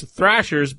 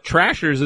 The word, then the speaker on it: Thrashers: Did I say it right? – It's Thrashers, but Trashers isn't a bad name for them because they were terrible.